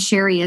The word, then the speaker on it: Sherrie: And Sherrie is S H E R R I E, since there's so many ways to spell that mm-hmm.